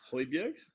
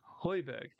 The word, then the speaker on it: Højbjerg: I'm gonna butcher the name. Hold on. – Højbjerg? – Højbjerg, thank you.